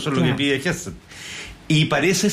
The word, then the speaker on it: Hegseth: Lo que pasa es que con el tema del escudo de las Américas, además, lo que dice Hechter... ¿Lo pronuncié bien? ¿Cuánto es? – Hegseth. – Hegseth. No es Hegseth. – No. – No, Hegseth.